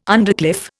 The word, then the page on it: undercliff: Indian